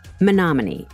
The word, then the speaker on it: Menominee: Menominee. It is spelled exactly as it sounds.